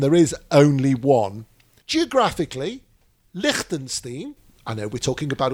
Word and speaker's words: Liechtenstein: Liechtenstein.